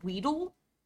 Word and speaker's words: Weedle: I do know it's Weedle.